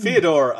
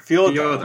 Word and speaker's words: Fyodor: No, because it's Brothers Karamazov or Fyodor Dostoevsky. We'll just go Fyodor. – Right? Yeah, we'll just do that. Go on. Fyodor. – Fyodor, as I would say it. It's the Russian equivalent of Theodore.